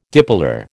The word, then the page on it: dipolar: American English